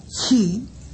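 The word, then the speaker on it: ĉi: La partícula chi, chi, la C con acento circunflejo, ya dijimos que la C con acento circunflejo se pronuncia como la Ch de muchacho, de chocolate. La C con circunflejo suena siempre che.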